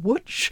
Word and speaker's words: Łódź: which is spelt L-O-D-Z, but pronounced Łódź, okay, and which the Germans renamed Litzmannstadt, the Vaterland, which was incorporated into the Greater German Reich.